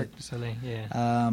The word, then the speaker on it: Cirque du Soleil: I've seen Cirque du Soleil. How do you pronounce it? – Cirque du Soleil.